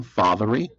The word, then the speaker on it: Fatherree: Yes, Dwayne Fathere, D-W-A-Y-N-E, last name F-A-T-H-E-R-R-E-E.